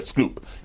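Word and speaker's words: Schoep: Shoup? – I'm sorry, Scoop. He pronounces it Scoop. – Scoop.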